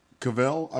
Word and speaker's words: Cavill: By the way, Henry Cavill, is that his name? – Cavill, I believe it's pronounced.